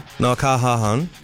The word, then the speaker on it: Noah Kahan: Noah Kahan.